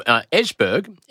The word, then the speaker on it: Esbjerg: And Tim was from Esbjerg. E-S-B-J-E-R-G. Esbjerg. – I see from your pronunciation you know where that is.